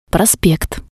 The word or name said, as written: prospekt